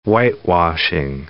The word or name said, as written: whitewashing